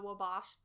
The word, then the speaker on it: Wabash: Then I, between the Fort Pitt and Liberty Tunnels is, I pronounce it the Wabash Tunnel. I know people pronounce it other ways, especially like people in Chicago say something different, I think.